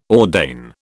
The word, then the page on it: ordain: British English